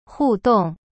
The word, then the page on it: 互动: hu dong